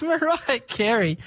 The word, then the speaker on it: Mariah Carey: Couldn't say her name properly. It's Mariah Carey with one of her older songs,